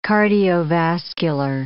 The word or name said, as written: cardiovascular